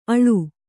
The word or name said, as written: ಅಳು